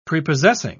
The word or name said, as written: prepossessing